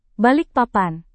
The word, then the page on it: balikpapan: indonesian